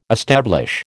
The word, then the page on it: establish: American English